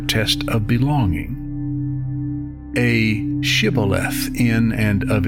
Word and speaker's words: Shibboleth: They asked everyone to say, "Shibboleth." Their perceived enemies, the Ephraimites, who were really fellow Israelites from a different tribe, would pronounce this Sibboleth.